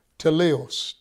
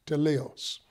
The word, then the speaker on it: teleios: Other meanings, but then the word for one particular word that is very significant is the word teleos. teleos, T-E-L-E-I-O-S, teleos. That means a complete man. A man it's the reference to Jesus, the Lord Jesus Christ, the teleos man, the perfect picture, the completed man.